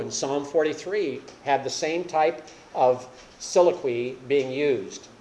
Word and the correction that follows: soliloquy: Soliloquy, excuse me, get it pronounced correctly there for those of you who teach English.